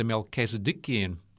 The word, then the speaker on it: Melchizedekian: The new sanctuary, of course, is not the Levitical priesthood, but the Melchizedekian. Did I say that right? – Pretty good.